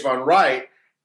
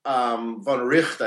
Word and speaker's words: von Wright: von Wright, von Richt, I think it's pronounced, had to sort of, you know, put this stuff all together and figure out what goes with what.